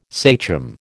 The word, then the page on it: sachem: American English